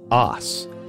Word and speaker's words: arse: And arse is how they mostly say it now, but the word arse in England was originally pronounced os with a long A sound. – The British will use an R to indicate a long vowel. They don't pronounce their R's in standard British. So A-R-S-E was just os and then became, I mean, people started to say arse, but initially that R wasn't there. – So originally rear end was os, A-R-S-E, and donkey is an A-S-S, ass.